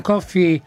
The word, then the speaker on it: Coffey: Senti, ma volevo dire, John Coffey... come la bevanda? Cos'è che dice lui nel film? Mi chiamo John Coffey. Si scrive, si pronuncia come la bevanda, si pronuncia uguale.